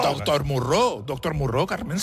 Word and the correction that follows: Moreau: ¡Se pronuncia Moreau! ¡Doctor Moreau!